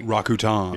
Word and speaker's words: Rakuten: so yeah, it officially from that commercial we learned it is Rakuten.